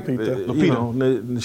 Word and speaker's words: Lupita: Lupita, did I get her name right? – Lupita.